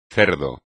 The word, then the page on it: cerdo: espagnol